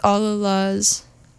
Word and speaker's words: All Alas: And this is All Alas, and it's spelled like Allah, like God, mm-hmm. But then Las.